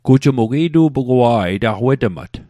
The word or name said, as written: wetmat